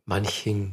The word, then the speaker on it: Manching: Manching.